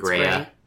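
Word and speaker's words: Graeae: The original Greek, the name is spelled G-R-A-E-A-E. G-R-A-E-A-E. So I'm going to say, like, Graia. – It's Graia. They're just calling Gray.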